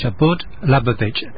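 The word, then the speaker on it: Chabad Lubavitch: It's spelt C-H-A-B-A-D, Shabud Lubavitch, L-U-B-A-V-I-T-C-H.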